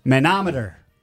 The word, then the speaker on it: manometer: This term came in, manometer.